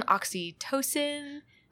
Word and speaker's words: oxytocin: And the bond is very similar to like, it's based on oxytocin. – Okay. – I almost said OxyContin. – Okay. – Did I say that right? Oxytocin. – Yeah, jeez.